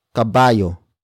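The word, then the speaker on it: kabayo: It comes from the root word caballo.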